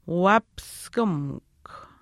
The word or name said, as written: waapiskimikw